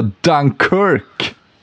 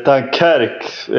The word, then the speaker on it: Dunkerque: Ingenting mindre än det historiskt kända Dunkirk. Eller hur uttalar man det på franska? – Ja, precis. Dunkerque är väl det fransk klingande uttalet.